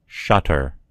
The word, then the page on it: shutter: American English